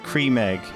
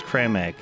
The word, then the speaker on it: Creme Egg: I just saw the Cadbury's Twitter had replied that cream egg is actually pronounced creme egg.